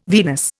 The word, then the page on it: Venus: Indian